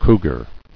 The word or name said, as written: Cougar